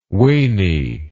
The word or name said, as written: wany